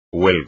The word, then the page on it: huelga: espagnol